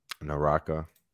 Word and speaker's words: Naraka: Naraka. N-A-R-A-K-A.